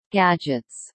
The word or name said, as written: gadgets